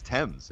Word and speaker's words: Thames: And it's Marcus Thames. – He pronounces it the other way. He's like the river.